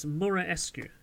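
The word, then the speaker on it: Muraresku: It's by Brian Marinescu, who's a let's get his name correctly. It's Marinescu.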